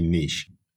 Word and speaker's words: niche: that would be a niche. It's probably a little bugbear for most Australians and Brits that there's different ways to pronounce that word, but in Australia we say niche.